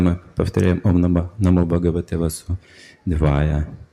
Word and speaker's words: वासुदेवाय: Ударение делается «васудевая». Уже другое ударение, как другое значение имеет. А здесь у нас не точный акцент, неуловимый акцент, я бы сказал. Неопределенное ударение. А ударение делается на последнем «васудевая».